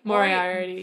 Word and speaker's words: Moriarty: How do you say it? Moriarty? – Moriarty, yeah.